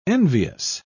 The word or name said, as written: envious